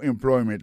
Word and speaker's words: employment: Alors, mot employment là, c'est employment. Accent tombé sous deuxième syllabe là.